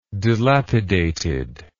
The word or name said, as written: dilapidated